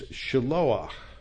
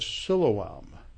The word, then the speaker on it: Shiloah: It's Shiloah. And Shiloah is a pool of water. You know it of its Aramaic name from the Gospel of John. It's the Pool of Siloam. And the Hebrew pronunciation is Shiloah.